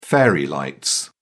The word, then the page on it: fairy lights: British English